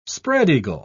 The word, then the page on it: spread-eagle: British English